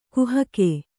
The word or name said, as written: ಕಾಹಿಗ